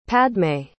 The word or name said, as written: padmay